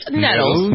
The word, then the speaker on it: Nettles: Do I pronounce Nettles? Is it Nettles? – You can be a little bit more pretty English sounding of you. It's Nettles. – Nettles. – It's Nettles. Nettles. Sounds like something you would stick in your butt.